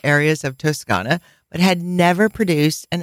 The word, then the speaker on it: Toscana: Allora, on to the region of Tuscany, which is pronounced Toscana in Italian,